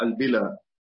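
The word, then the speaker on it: البلى: ما هي أنا أصلا جاءت على الخاص وقالت لي ياء، وهي ليست بياء، هي أنا قلتها البلا بفتح الباء على أن الفعل أصلها من بلا الشيء، بلا الشيء يعني إذا بلا يعني إذا استهلك، لكن هي في الحديث تنطق البلا بكسر الباء وفتح اللام، ليس فيها ياء أصلا.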